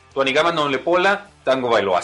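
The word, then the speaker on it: Tuanigamanuolepola Tagovailoa: Tuanigama pola Tango Bailoa. Tuanigama nulepola Tango Bailoa. Así se pronuncia y por eso mejor de cariño entre cuates le decimos Tua.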